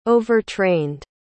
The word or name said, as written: overtrained